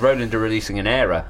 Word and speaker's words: Aira: This is the Roland Ira series. – I don't understand the pronunciation. – Ira. – Aira. It's spelled with an A.